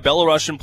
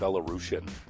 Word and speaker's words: Belarusian: Can Yegor Sharangovich tie or pass Mikhail Grabovsky for most points by a Belarusian player? 58 is the record. He's currently at 57? – I think it's actually pronounced Belarusian.